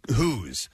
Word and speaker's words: Hoos: So says, hey, guys, my friend Gus Hoos... has a birthday today. His last name is spelled H-O-O-S, and it's pronounced like goose, he said here.